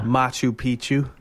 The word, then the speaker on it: Machu Picchu: Machu Picchu.